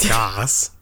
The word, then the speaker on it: Gass: We've gone almost half a year doing a show together, and I just found out you don't know how to say my last name, which is pronounced David Gass.